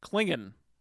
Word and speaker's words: Clingan: And today we are discussing William Clingan. – Clingan, like the Klingon, but Clingan.